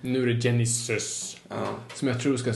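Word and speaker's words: Genisys: Inte som Phil Collins Genesis. Nej, för det är med I. Genesis. Ja, precis.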